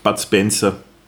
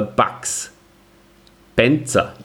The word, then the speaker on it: Bud Spencer: Und zwar, ähm, hast du Bud Spencer, wie hast du den, hast du den immer schon so ausgesprochen oder wie hast du den früher ausgesprochen als Kind? Bud Spencer. Wirklich? Ja. Okay. Ich zum Beispiel habe mir immer gedacht, der heißt äh, Bugs Spencer.